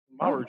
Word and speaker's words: Maruchan: How do you say it? – Maruchan. – Just like you said.